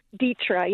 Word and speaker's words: Deatra: Is that how I pronounce your name properly? – Dietra, yes. – Dietra, okay. – D-E-A-T-R-A, yep.